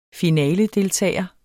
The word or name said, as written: finaledeltager